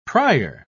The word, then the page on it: prier: British English